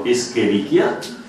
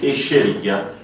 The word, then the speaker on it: Escherichia: se lo possiamo pronunciare alla latina, cioè all'italiana, e quindi pronunciarlo Escherichia, o possiamo pronunciarlo alla, come, alla tedesca, nel senso quel nome come sarebbe Escherichia coli. Le due cose sono equivalenti.